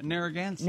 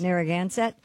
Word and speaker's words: Narragansett: Narragansett. – Narragansett.